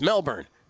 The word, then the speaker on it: Melbourne: I'm like, Ron, it's Melbourne. It's Melbourne, Gil. That's how the natives pronounce it.